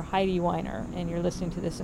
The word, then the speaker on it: Weiner: Carmeet was kind enough to let me know that Jennifer Weiner pronounces her last name Weiner. Carmeet is German. She lives in Germany. And, of course, you know, in German, and Weiner, I think, seems like a fairly German name. It's E-I. If you have E-I, you pronounce the second vowel in the vowel combo. So I know that.